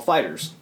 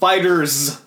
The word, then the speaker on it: FighterZ: Um, the current one that I'm playing right now is used at a lot of popular fighting game circuits is Dragon Ball FighterZ. Or Fighter Z. It's pronounced fighters. What is Dragon Ball Z? Why would you call it Fighter Z? It's fighters.